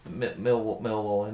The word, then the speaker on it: Millwall: But I'm pleased to hear that you pronounced it as Millwall and not Millwall, because quite a few people seem to, especially people who support Millwall, seem to put the emphasis on the wall bit rather than the mill bit.